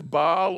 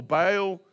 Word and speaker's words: Baal: The guy on your left here, it depends how you want to pronounce this, Baal or Baal.